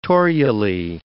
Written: torulae

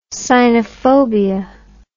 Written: sinophobia

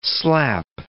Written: slap

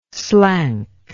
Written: slank

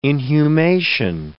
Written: inhumation

